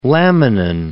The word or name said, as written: laminin